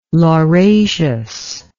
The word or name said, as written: lauraceous